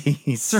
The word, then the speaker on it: Cersei: Not Xerxes. Cersei. – Oh, sorry. Cersei.